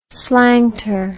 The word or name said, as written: slanter